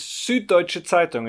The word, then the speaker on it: Süddeutsche Zeitung: Süddeutsche Zeitung. Did I get that almost right? – Yeah, almost. – Almost. How, what's the correct pronunciation of your organization? – It's Süddeutsche Zeitung. It's an easy word, right?